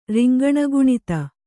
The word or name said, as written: ರಿಂಗಣಗುಣಿತ